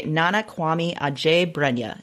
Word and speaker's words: Nana Kwame Adjei-Brenyah: So for an other genre pick, I have chosen a collection of short stories called Friday Black by Nana Kwame Adjei-Brenya. And I apologize if I mispronounced that. – It sounded great.